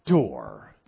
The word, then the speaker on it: Dor: It says Noah was perfect in his Dor, D-O-R-E. That's how it's pronounced. It's D-O-W-R in the Hebrew, transliterated.